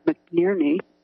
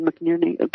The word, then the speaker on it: McNerney: They had their congressman who was an ex-PG&E lobbyist who turned congressman, Congressman Jerry McNierney, I think that's how you say it, McNerney.